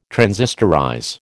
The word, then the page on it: transistorize: American English